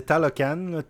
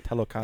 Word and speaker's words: Talocan: Ben, je, sais pas la prononciation exacte, mais euh, c'est Talocan, T-A-L-O-C-A. – Ben, c'est ça, voilà. Okay. Talocan.